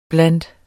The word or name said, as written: blandt